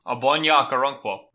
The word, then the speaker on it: Ogbonna Okoronkwo: Ogbonna Okoronkwo. – Oh, geez. There we go. – The G is like soft. It's like Ogbonna.